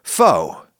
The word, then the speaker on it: pho: You think of like a boiling hot bowl of pho, or pho, I guess it's pronounced. Apparently some people in Vietnam actually pronounce it pho in addition to pho, but anyway, pho.